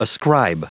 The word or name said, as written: ascribe